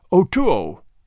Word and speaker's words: O'Tuo: "O'Tuo," he told me his name was, pronounced O-2-O.